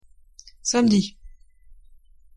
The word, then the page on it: samedi: French